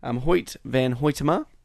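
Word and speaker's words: Hoyte van Hoytema: Hoyt Van Hoytema.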